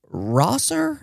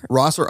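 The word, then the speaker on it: Roeser: I think it's pronounced Rosser. – Rosser, okay. – I don't know. I could be wrong. It's R-O-E-S-E-R.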